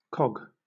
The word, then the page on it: cog: British English